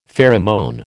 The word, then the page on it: pheromone: American English